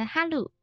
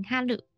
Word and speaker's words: 春: 春夏秋冬，春天就是哈鲁，一号音哈鲁。